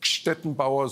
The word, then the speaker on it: Gstettenbauer: Ich bin ja wenigstens in München zu Hause, also insofern kann ich das Gstettenbauer so einigermaßen, so ein bisschen wie Gspusi, ne? Das genau. – So dieselbe Aussprache.